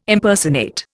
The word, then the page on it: Impersonate: Indian